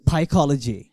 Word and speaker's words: psychology: so in psychology p is silent